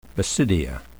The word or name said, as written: basidia